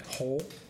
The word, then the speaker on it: Hull: How do you say the town's hull? – Hull? – Hull. – Like the hull of a ship.